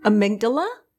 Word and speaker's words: amygdala: But the amygdala? – Amygdala? – Amygdala. – Yep, good try. – Yeah, you had the emphasis on the wrong syllable.